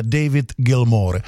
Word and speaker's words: David Gilmour: To jméno je David Gilmour, jo? Ne Gilmour, někdo dokonce říká Gilmour, ale David Gilmour.